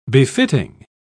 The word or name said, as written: befitting